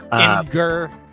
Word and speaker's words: Imgur: There is an art book for this game, which I will put a link to in the description, because it's just an how do you pronounce that? Imgur? I-M-G-U-R? Whatever. – Imgur.